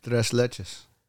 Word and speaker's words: Tres Leches: That Tres Leches. Did I say that right? – Tres Leches.